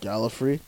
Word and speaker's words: Gallifrey: You pronounce it like Tom Baker used to pronounce it. – Gallifrey? – Yeah. Hmm. He pronounced Daleks Darlek, and Gallifrey is Gallifrey.